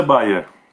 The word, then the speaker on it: Bier: Então, inclusive, se você aí quiser seguir ele, o arroba dele é Fernando Bier. Bier. Não sei como fala. Eu acho que é Bayer. É Bayer? – Será? – A pronúncia é Bayer.